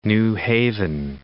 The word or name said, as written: New Haven